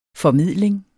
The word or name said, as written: formidling